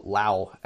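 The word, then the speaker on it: Lhaw: Amon Law, over on, well, Lao actually is how it should be pronounced, on the other side of the Anduin.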